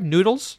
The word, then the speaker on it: Nudels: Do I pronounce that right? Noodles? Noodles? Nudels? – Yep, noodles, yep. – Noodles, the noodles, like noodles, okay? Like pasta.